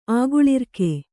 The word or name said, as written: ಆಗುಳಿರ್ಕೆ